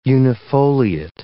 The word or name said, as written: unifoliate